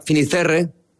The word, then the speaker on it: Fisterra: Entonces llegó a Finisterre, ¿se dice así? – Fisterra. – Es como se pone lo gallego también, en fin.